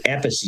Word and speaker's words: Appice: It used to be Appice is the right way to say it.